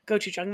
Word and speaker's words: gochujang: So I put a little bit of gochujang. Yeah. Gochujang, that's how it's pronounced.